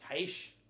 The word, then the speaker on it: Aitch: The first one being My G by Aitch. I don't know how to say it. It's Aitch. – Aitch.